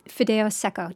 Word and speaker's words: fideo seco: Okay, tell us about fideo seco. Did I say that right? – You did say that right.